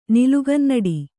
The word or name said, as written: ನಿಲುಗನ್ನಡಿ